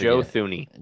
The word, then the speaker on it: Joe Thuney: Joe Thuney. – Joe Thuney, that's right.